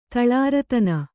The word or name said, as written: ತಳಾರತನ